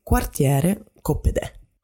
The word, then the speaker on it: Quartiere Coppedè: We call it Quartiere Coppedè.